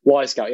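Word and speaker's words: Wyscout: Well, it's, well, as in like spelt WY Scout. I've always pronounced it Y Scout, but I've never heard it like said, you know? – Yeah, exactly. I think I might miss it. I've been using it a couple of years now. Y Scout, yeah, that's it. It's spelled WY Scout, whatever.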